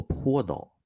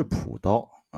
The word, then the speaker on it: 朴刀: 先更正一个错误，上一节呢，咱们讲这个武松带的刀，我们应该是读作坡刀，它不是朴刀啊。